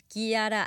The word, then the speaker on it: Chiara: Y acuérdense que para pastas, la que se pinta sola es nuestra queridísima amiga, la chef Kiara Avente, que la van a encontrar también así en sus redes sociales como chef Kiara, que se escribe Chiara, punto, bueno, Avente, Kiara Avente, punto em.